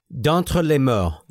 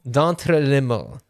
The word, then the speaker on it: D'entre les morts: I can speak French. Hold on. – Sucks. – D'entre les morts. – D'entre les morts. There we go.